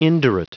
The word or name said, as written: indurate